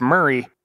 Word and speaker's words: Maury: And remember, Murray County is where I've been turkey hunting for a couple years now, and that's spelled M-A-U-R-Y. – You are the father. – For all of us northern folks, that's pronounced Murray in Tennessee, as in Bill.